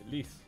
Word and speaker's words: Lis: Mateus L-I-S. No, no, es más guapo. Pero es guapo. – L-I-S. Liz.